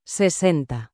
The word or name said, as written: 60